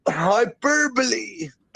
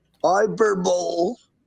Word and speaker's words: hyperbole: So in the context of Scripture, it becomes clear that Paul was speaking by means of hyperbole. I used to pronounce this word as hyperbole, but my wife actually corrected me. It's an hyperbole.